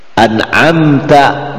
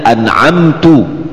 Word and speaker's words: an'amta: An'amta, dibacanya an'amtu, salah, harus diperbaiki oleh makmum.